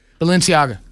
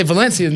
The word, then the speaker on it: Balenciaga: Say it. Balenciaga. – Yeah. Man. – Balenciaga. – I don't remember how to say it. I was about to say Valencia. Them's oranges.